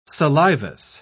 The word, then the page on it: salivous: British English